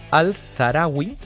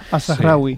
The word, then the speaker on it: Al-Zahrawi: Pues mira, el primero que he traído es Al-Zarawi. Ya me dirá si lo he pronunciado bien o mal. – Al-Zahrawi.